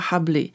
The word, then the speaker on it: Rabelais: 因为今天要讲的这个人呢叫做 François Hably，他是非常重要的一个作家，他的中文的翻译叫做拉伯雷，但是法文的发音就是 Hably，就比较接近哈布雷。